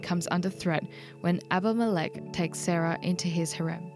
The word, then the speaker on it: Abimelech: I think straight up when starting this chapter, I want to know a bit of background context on Abimelech.